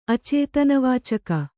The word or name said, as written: ಅಚೇತನವಾಚಕ